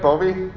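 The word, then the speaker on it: Bovey: So this is a picture of a man named Charles Wilden from Bovie, Minnesota. This is, did I say it right? Bovie? Yeah.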